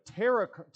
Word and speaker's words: tarot: instead of tarot cards, right? I think the T is silent in that.